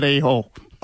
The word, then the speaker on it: Aho: Sebastian Aho, who you should know, and is it Aho? – I like Aho better. – No, of course. Aho is how it's pronounced.